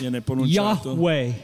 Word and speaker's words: Yahweh: Sometimes it's pronounced Yahweh.